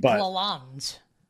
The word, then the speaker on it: Lalonde: I feel like I pronounce it's like Lalonde or something. I forget how to say it. – Derek Lalonde.